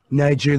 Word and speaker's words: Niger: Niger, that's a soft G, by the way.